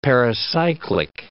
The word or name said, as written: pericyclic